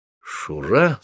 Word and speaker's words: Шура: Шура? С ударением на последний слог? Так точно.